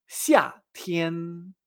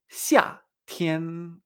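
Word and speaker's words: xia Tien (夏天): Verano en chino se dice xia Tien. xia tian.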